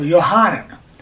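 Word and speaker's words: Yohanan (יוחנן): And Hanan, H-N, is H-A-N-N-O-N. So Yohanan. That's the Hebrew word that's being pronounced here, and not John.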